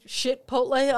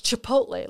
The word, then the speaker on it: Chipotle: And I'm like, oh, did you say Chipotle on purpose? And she's like, that's how you pronounce it, Chipotle. And I'm like, it's Chipotle, like ch.